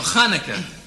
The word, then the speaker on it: Hanukkah: Chanukah, that's beautiful. – What, you mean Hanukkah? – Yeah, Hanukkah. – No, Hanukkah. CH is pronounced chuch.